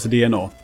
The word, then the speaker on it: DeNA: D-E-N-A. – Ja, men det uttalas DNA. Det är bara att de heter så. Ja, D-E-N-A. DNA.